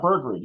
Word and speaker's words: Bergrud: Yeah, Bergrud.